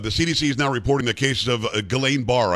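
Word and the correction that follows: Guillain-Barré: I think that's how you say it, syndrome after being vaccinated. What is that, and, and uh, how did this show its face? – Guillain Barre syndrome, I see at my clinic, people have taken the vaccine.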